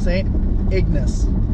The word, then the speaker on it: St. Ignace: I cannot pronounce the name of that. It's Agnes, St. Ignatius, St. Ignacio. I don't know what the hell the name of the city is. St. Ignace. Yeah? – Yeah, that's right.